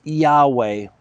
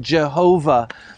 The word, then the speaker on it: Yahweh: Now, many scholars today, uh, Jewish scholars, believe that YHWH is pronounced Yahweh, right? Yahweh. But but again, we, we don't really know. For for a time, uh, there were some uh, scholars who thought it was pronounced Jehovah.